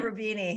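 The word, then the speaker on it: Roubini: Blockchain, And so, but you handled it very well to say, you know, hey, even I get Rubani. Am I saying his name right? – Rubini, yeah.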